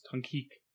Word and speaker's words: Tonkik: So like Tonka, but it's Tonkik.